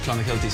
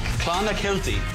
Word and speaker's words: Clonakilty: Irish tea bags or Clonakilty sausages from Cork. – Clonakilty. – Clonakilty.